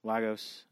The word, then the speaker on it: Lagos: She's already caused death in Lagos. Or is that how you pronounce it? – Lagos.